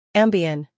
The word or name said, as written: Ambien